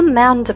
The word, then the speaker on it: amount: No amount of crying. Amount, 这里面的元音 ow 这个音呢大家也要发得尽量饱满一些。